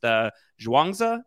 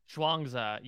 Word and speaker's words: Zhuangzi: We believe it is pronounced Zhuangzi. Is that right? – Zhuangzi, yeah. Yes.